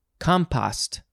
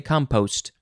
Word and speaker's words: compost: All right, next is compost, and it looks like the Brits say compost, and we say compost.